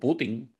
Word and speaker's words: Putin: Pero bueno, en otro tema, digo, antes de entrar en el tema que quiero traer, leí que el doble de Vladimir Putin, o Putin, porque no lleva acento en la I, pues teme por su vida, ¿verdad?